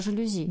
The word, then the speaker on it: жалюзи: В основном говорят э, с ударением на первый слог, хотя норма жалюзи.